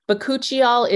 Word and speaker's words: bakuchiol: It's spelled B-A-K-U-C-H-I-O-L, bakuchiol. I